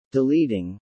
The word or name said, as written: deleting